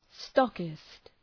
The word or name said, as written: stockist